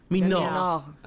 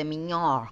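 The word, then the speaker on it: De Miñaur: ¿Minaur? De Minor. – Minor. – Porque es con ñe, de hecho, de Miñor. Ah, ok, bueno. el señor Miñor.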